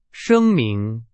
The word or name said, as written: 声明